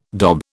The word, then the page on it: dob: British English